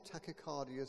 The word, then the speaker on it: Tachycardia: Is that pronounced correctly? Near enough. Postural Tachycardia Syndrome.